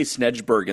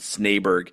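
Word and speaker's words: Snejbjerg: I used to say Snedgeberg, and then I actually heard it Yeah. – pronounced as Snaberg.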